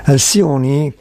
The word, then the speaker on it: Alcione: Alcioni, che si scrive Alcione, è una delle grandi personalità artistiche legate alla Manghera, intesa come favela, come quartiere, come scuola di samba.